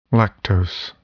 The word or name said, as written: lactose